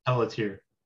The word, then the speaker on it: Pelletier: Pelletier.